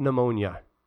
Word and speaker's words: pneumonia: How many of you know about pneumonia? Or as we call it in our house, pneumonia, right? Because that P is silent. But it's P-N-E-U-M-O-N-I-A. P-N-E-U-M-O-N-I-A.